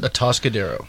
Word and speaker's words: Atascadero: That's what we decided. That's how you pronounce it. – Atascadero.